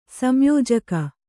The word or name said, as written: ಸಂಯೋಜಕಿ